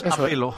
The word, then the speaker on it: apelo: Apeló... No, Bobby, no lleva tilde. Es apelo. Apelo, no apelo.